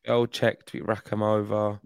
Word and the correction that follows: Bejlek: I looked up yesterday. It's Bejlek. That's how you say it.